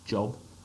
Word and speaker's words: Job: Remember, it's spelled J-O-B, Job, that's but pronounced Job.